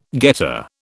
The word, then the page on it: getter: British English